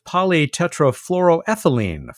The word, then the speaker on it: polytetrafluoroethylene: They were made of polytetrafluoroethylene floating in an atmosphere of toxic acid rain, measuring the pressure of the wind speed hurricane.